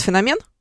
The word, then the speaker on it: феномен: Потому что многие говорят феномен. – В связке. – Да. Фетиш. Феномен фетиша. – Угу, да. – Да-да-да. – Феномен. – Феномен, да, греческое происхождение. – Да. – Поэтому ударение на второй слог.